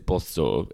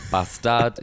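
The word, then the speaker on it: Båstad: Well, you already have, Joel, with that, because it is Bostor. It's not Bastad, it's not Gastad, it's none of those, Joel.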